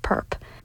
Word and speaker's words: PRP: This is PRP, or Permanent Research Project, pronounced PERP.